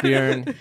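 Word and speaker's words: Björn: It's Bjorn. It rhymes with urine.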